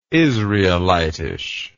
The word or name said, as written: israelitish